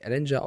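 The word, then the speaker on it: Ehlinger: Also erst wird der, wird der lieber Matt Ryan gebancht, dann soll Sam Ellinger, also er möchte nicht Ellinger ausgesprochen werden, sondern Ellinger, wie er jetzt selber gesagt hat, darf spielen und dann verlieren sie mit einem Last-Minute-Drive von Taylor Heinecke das Spiel und jetzt muss der OC gehen.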